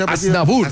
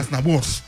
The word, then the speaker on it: Aznavour: Y un día como hoy, pero el año 2018 muere Charles, ¿cómo se pronuncia? – Aznavour. – Aznavour, por favor.